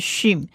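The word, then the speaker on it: shin (שׁ): 再来第二个字母是希伯来文字母 shin， shin 它的黑点在右上方，所以它是 shin，如果黑点在左上方是 sin。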